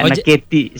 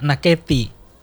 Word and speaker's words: nakete: Ya, kalau dia ada aksen E-nya jadi nakete. Kalau orang sebut nakete kan agak-agak bunyi mananya berbeda. Ya, nakete sebenarnya itu. – Oh, jadi cara bacanya ya, nakete.